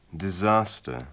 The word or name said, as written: disaster